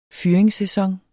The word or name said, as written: fyringssæson